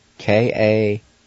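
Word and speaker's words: Kagali: Then it's spelled U-N-O-L-V-T-A-N-A. Again, U-N-O-L-V-T-A-N-A. Number two, February. Then it's spelled K-A-G-A-L-I. K-A-G-A-L-I. Number three is March, and it's spelled A-N-V-Y-I. A-N-V-Y-I.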